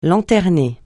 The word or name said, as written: lanterner